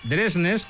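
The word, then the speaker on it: Dversnes: con Fredrik Dresnes, que es como se pronuncia su apellido, él mismo lo pronuncia así,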